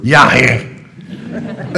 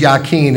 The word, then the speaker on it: Yachin: Here's how you do it in Hebrew. Yachin. That is how you pronounce it in Hebrew, but we're going to use Yachin. It's just better English.